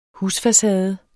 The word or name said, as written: husfacade